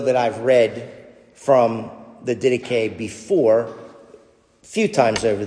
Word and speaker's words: Didache: This is an excerpt from the Didache, or Didache, as some people pronounce it, otherwise known as the 12 Apostles.